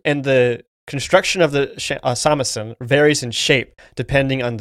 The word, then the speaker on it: shamisen: Also, we talked about this in our synopsis, or we mentioned it, but the three-stringed instrument that Kubo plays is called a samisen. It's spelled shamisen, but pronounced samisen, and it's a traditional Japanese musical device.